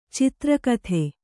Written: ಚದುರೋಕ್ತಿ

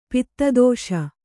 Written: ಪದದೋಷ

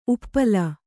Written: ಉಪ್ಪಲ

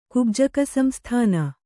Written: ಕುಬ್ಜಕಸಂಸ್ಥಾನ

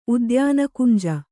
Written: ಉದ್ಯಾನಕುಂಜ

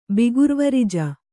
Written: ಬಿಗುರ್ವರಿಜ

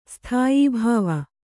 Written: ಸ್ಥಾಯಿಭಾವ

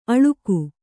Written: ಅಳುಕು

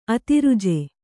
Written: ಅದ್ರಿಜೆ

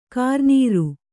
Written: ಕರಿನೀರು

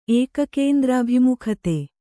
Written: ಏಕಕೇಂದ್ರಾಭಿಮುಖತೆ

